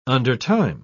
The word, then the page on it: undertime: British English